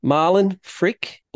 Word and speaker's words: Malin Frick: Malin Frick, all the way from Sweden.